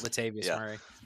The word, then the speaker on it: Latavius Murray: And running back is one of those positions where you can go out and you can go sign, a Levante Murray, and he's going to give production for you. I just said that wrong. Man, that's embarrassing. Latavius Murray.